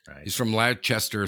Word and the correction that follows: Leicester: No, that'd be Leicester. – Leicester. – Jester from Leicester. See, it rhymes.